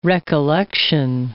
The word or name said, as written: recollection